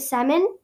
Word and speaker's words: salmon: So now it's spelled S-A-L-M-O-N. And the spelling was changed, but the pronunciation stayed the same.